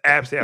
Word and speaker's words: ABCDE: A-B-C-D-E is how it's spelled. I've seen that name before. No bullshit. – Shut up, I'm not bullshit. No bullshit. I'm not lying. I swear, hand on the Bible. Absidy. That's a real name. I'm not bullshit. – No, it isn't. – All right. Hand on the Bible. I swear to God. I didn't make that up just now. I am for real. They pronounce it Absidy.